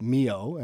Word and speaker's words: Milhaud: So we're performing a work by Darius Mio, and it's spelled as though you would pronounce it Milhaud.